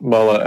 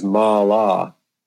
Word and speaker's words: mala: There's another one that, you know, the word mala, which some people call the sacred necklace, but mala actually means a human waste product, urine, feces, or sweat. The correct pronunciation is mala for the sacred necklace.